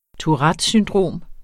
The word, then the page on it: Tourettes syndrom: tuˈʁads synˈdʁoˀm; tuˈʁadəs synˈdʁoˀm